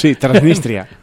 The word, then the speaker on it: Transnistria: Transnistria.